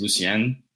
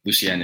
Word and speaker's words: Lucien: So that's the American way to say it. So my dad's side of the family, they're Haitian. So the correct way to say it is Lucien, but growing up, like, a lot of people will mispronounce it.